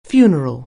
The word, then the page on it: funeral: anglais